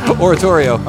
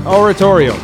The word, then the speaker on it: oratorio: Oratorio. – Oratorio.